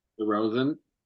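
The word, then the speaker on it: Rozan: De how do you say DeRozan? Rosen? – Rosen.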